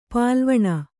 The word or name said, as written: ಪಾಲ್ವಣ